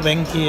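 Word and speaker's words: Venchi: Bueno, Benchi escrito, Benki en pronunciación italiana.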